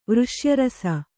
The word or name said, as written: ವೃಷ್ಯರಸ